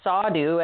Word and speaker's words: Sadhu: M-A-T-A-J-I, Sadhu, S-A-D-H-U. That's a silent H when you say it, though.